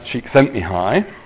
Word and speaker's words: Csikszentmihalyi: His name's pronounced Csikszentmihalyi, or at least that's the best my English voice will do on the Hungarian name.